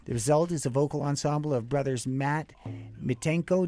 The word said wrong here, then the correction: Mintenko: Did I say it right, Mitenko? – Mintenko. – Mintenko. – Close enough.